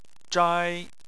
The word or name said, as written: zhai1